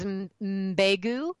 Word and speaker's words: Mbegu: And the first baby that we adopted, her name is Mbegu, and that's an African pronunciation. You start with an M and then go right into another consonant, and it means a little seed because she was a little seed of such good things.